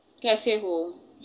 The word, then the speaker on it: kaise ho: How like, do you do? How do you say that? – Kaise ho. – Say that again. – Kaise ho. – Kaise ho. Kaise ho. – Yes, kaise ho.